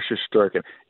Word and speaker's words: Shesterkin: Igor Shesterkin? Is that the way you pronounce it? Shesterkin? – You nailed it. Igor Shesterkin.